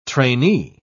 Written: trainee

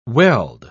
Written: weld

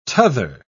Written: tother

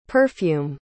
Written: perfume

